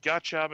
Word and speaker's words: gacha: At least I assume it's pronounced Gacha. It's G-A-C-H-A. – Did I pronounce that right? Let me take another look at this. I think you are pronouncing it right.